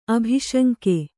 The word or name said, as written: ಅಭಿಶಂಕೆ